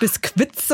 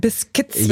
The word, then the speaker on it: biscuits: Nicht Biskuits, sondern Biskits wird ausgesprochen.